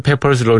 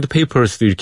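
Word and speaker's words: Pepper's: 그 중에서 뭐 서전 페퍼스 러니 아스 크럽 밴드를 자꾸 서전 페이퍼스도 이렇게 얘기를 하거든요. 그게 왜 그런지 저도 잘그 페퍼스라는 걸 제가 몰라서 그런 건 아니고요. 예. 그 어, 페퍼 상사 예, 알긴 아는데 그렇게 이상하게 발음이 되더라고요.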